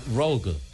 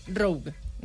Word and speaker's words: Rogue: El Rogue. – Rogue.